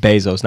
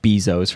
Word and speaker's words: Bezos: It's pronounced Bezos, not Bezos for everybody that pronounces it like that.